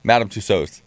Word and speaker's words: Tussauds: How do you say? – Madame Tussauds? Madame Tussauds.